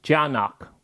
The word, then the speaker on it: Tiyanak: Those are called Tiyanak, and then you spell it T-I-Y-A-N-A-K. Fun fact, A-N-A-K, Anak, means child.